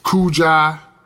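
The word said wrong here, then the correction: Kujichagulia: Kuiji. – It's Kujicha. Hold on, Kujicha. Kujicha Gulia.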